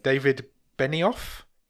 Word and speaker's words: David Benioff: David Benioff? – Yes.